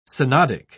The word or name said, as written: synodic